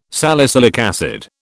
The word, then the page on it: salicylic acid: British English